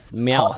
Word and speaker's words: Meowth: How do you pronounce that? Hot Meowth? – Meowth. Yeah.